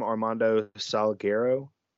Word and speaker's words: Armando Salguero: So, apparently this is a real thing, but Armando, how do you say his name? Armando Salguero? – Correct.